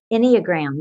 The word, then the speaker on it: Enneagram: Enneagram, yeah.